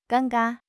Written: 尴尬

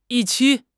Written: 预期

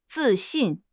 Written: zi4 xin4

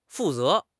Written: fu4 ze2

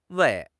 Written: wei4

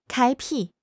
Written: 开辟

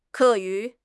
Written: ke4 yu2

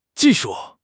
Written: ji4 shu4

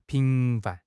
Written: 频繁

